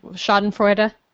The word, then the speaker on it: Schadenfreude: Like Schadenfreude? – Yeah, I mean, my favorite, I think, is...